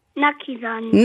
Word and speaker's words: Naki Sani: Habe ich dich richtig ausgesprochen? – Nein. – Na, sag mal, wie heißt du richtig? – Naki Sani.